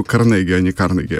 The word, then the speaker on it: Карнеги: Да, вставил своих 5 копеек, типа, вообще-то не Карнеги, а Карнеги, а на самом деле Карнеги.